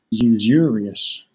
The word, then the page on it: usurious: British English